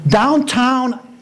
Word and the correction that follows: Downton: And I went online, and I'm like, D-O-W-N-T-O... T-O-N is how it's spelled. D-O-W-N-T-O-N. Downton.